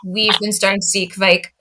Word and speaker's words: Kveik: Am I pronouncing Kveik correctly? – Yeah.